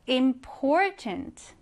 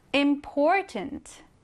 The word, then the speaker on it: important: 자, 얘를 읽을 때는요, 강세를 이렇게 가운데에 넣어주세요. Important. Important. 이렇게요.